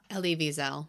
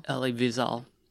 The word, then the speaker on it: Elie Wiesel: Elie Wiesel. – Elie Wiesel.